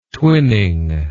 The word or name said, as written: twinning